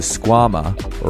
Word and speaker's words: Squama: The Squama, or rhymes with your mama, is a high-performance Velcro slipper aimed at getting you boulderers up your sickest project.